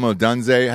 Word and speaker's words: Odunze: Next up is that wide receiver, that Odunze kid, Rome Odunze. How do you pronounce that? – Yeah, I think you got it.